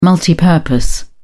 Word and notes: multipurpose: British English

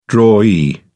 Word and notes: drawee: British English

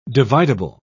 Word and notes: dividable: British English

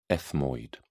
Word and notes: ethmoid: British English

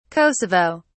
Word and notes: kosovo: British English

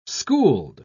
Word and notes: schooled: British English

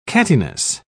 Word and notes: cattiness: British English

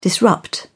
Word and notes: disrupt: British English

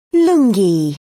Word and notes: lungi: British English

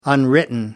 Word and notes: unwritten: American English

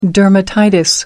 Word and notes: dermatitis: American English

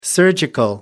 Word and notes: surgical: American English

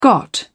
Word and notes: got: British English

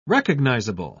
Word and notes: recognisable: British English

